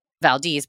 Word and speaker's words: Valdez: Valdez, by the way, long E.